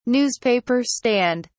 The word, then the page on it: newspaper stand: British English